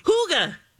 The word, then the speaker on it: Hygge: This is the new Haigi? – Hygge. – This is the new Hygge? – Hygge.